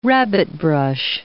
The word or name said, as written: rabbitbrush